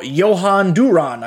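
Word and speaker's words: Jhoan Duran: Tops now, Johan Duran, I've learned is how you say his name.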